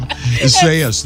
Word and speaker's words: Isaias: Speaking of time, Grace and I spent most of the last 10 minutes learning how to pronounce this tropical storm, Isaias, that's out there right now.